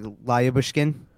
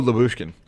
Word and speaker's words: Lyubushkin: No, like Lyubushkin. – Oh, Lyubushkin.